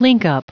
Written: linkup

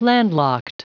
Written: landlocked

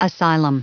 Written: asylum

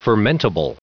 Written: fermentable

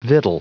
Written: victual

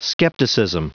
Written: skepticism

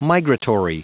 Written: migratory

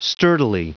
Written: sturdily

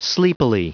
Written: sleepily

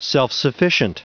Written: self-sufficient

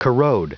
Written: corrode

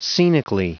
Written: scenically

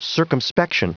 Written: circumspection

